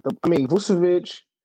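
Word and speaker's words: Vučević: I mean Vucevic, you know, that's how you pronounce it, Kendrick Perkins, but Vucevic, he's basically one of those, like, he adds to that team so much, right?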